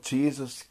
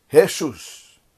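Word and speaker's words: Jesús: So sometimes you will see a movie or a series on TV and they call this guy, they call him Jesus, Jesus. Because in Spanish the J is pronounced like an H. That's why the guy, if the name is written J-O-S-E with an apostrophe on the E, we will read it as Jose, but in Spanish it is read as Jose, Jose.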